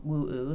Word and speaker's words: WoO: The book is called Woo-Oo. That's how you pronounce it? – Woo. – Woo. – Mm-hmm. – Okay. So it's interesting, though, the way it's spelled is capital W, mm-hmm. small O, and then is that a big O or a zero mm-hmm. at the end? – It's a big O. – Okay. – Yeah. – But it's pronounced Woo.